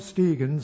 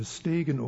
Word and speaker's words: Stegen: Erlo Stegen soll, also man könnte Stegen oder Stegen sagen, er kommt aus Südafrika, er dürfte deutscher Herkunft sein und somit dürfte sein Name Stegen ausgesprochen werden, aber wenn er holländisch ausgesprochen wird, dann würde es Stegen heißen, und in Südafrika hat er sehr viele holländische Herkunft.